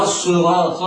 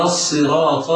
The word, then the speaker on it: الصراط: ولكن تفخيمها أبلغ من تفخيم الخاء والغين والقاف لأنها حرف استعلاء وإطباق، ولكن لا يبالغ القارئ في تفخيمها فيشربها حركة الضم، فلا يقول خطأ اهدنا الصراط المستقيم، هذا خطأ، والصواب أن ينطقها مكسورة هكذا: اهدنا الصراط المستقيم ولا يخفي صفيرها.